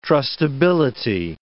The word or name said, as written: trustability